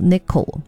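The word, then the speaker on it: ねこ: 但是其实他们用的是就刚好是一个代表本地的这个 Highway，但是因为日文的猫 Neko， N-E-K-O，对，也是这个发音，所以也是把他们的特色就是那种皎洁轻快而且充满都市漫游感的那种音乐美学给带出来。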